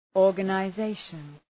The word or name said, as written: organization